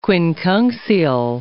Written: quincunxial

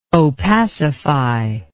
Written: opacify

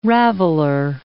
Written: raveler

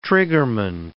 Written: triggerman

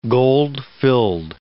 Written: gold-filled

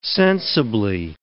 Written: sensibly

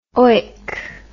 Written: oick

